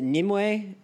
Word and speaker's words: Nimue: is it Nimue, am I saying it right? – Yeah, she's actually, you know what, I was gonna give Arthuriana background, but Elijah, you take this one.